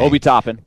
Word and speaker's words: Obi Toppin: Obi Toppin. – Obi Toppin.